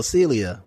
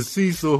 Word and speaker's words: Cecil: And the moment you mentioned Hotel Cecilia, my mind was like, anyway, I'm just mad y'all can never say it correctly. Y'all have said it 18 different times already. It's the Cecil Hotel.